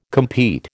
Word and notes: compete: American English

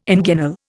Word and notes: inguinal: Indian

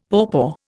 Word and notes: pawpaw: Indian

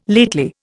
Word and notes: lately: Indian

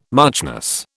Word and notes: muchness: British English

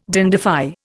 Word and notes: dandify: Indian

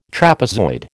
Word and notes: trapezoid: American English

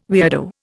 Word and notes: weirdo: Indian

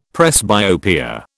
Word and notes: presbyopia: British English

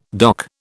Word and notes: doc: British English

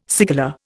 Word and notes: cigala: Indian

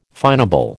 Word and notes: finable: American English

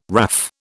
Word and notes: WRAF: British English

